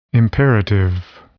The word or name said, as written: imperative